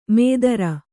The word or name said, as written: ಮೇದರ